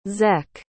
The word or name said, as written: zek